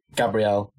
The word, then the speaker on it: Gabriel: It could be Gabriel, that's how his name is pronounced.